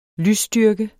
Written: lysstyrke

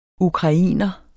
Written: ukrainer

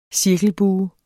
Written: cirkelbue

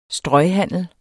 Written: strøghandel

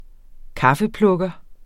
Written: kaffeplukker

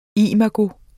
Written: imago

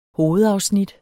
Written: hovedafsnit

